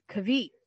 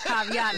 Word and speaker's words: caveat: So for the longest time, I would say, like I would read it as Kavit. And then I'm watching Bones and caveat. – And she says caveat, and I'm like, you dumbass.